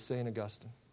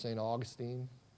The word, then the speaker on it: Augustine: a classic book from what we often refer to as St. Augustine. Or if we pronounce it in English, St. Augustine. A lot of us people pronounce it that way.